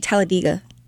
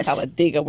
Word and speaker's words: Talladega: Actually, people in Alabama, it's actually Talladega. – Oh, yes. That's how you say it, Talladega. – Yeah. Never heard how you say it. – It really is how you say it. But okay. – Over time, it kind of changed to Talladega. – So, but everybody says Talladega, except for the people from Talladega.